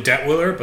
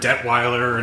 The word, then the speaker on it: Detwiller: It's usually Detwiller, but... I've heard Detweiler, and it's spelled like 33 different ways, but we're all related. You find a Detwiller, or a Detweiler, or a Det, you know, 1L, that's us too.